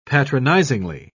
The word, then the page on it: patronizingly: British English